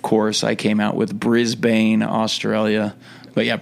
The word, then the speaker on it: Brisbane: Yeah, I would say, is Brisbane the American saying of it? – No, Brisbane is the correct way to say it. – Brisbane is. – Brisbane is the incorrect way.